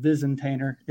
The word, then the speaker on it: Visintainer: Just like it's spelled, Visentainer.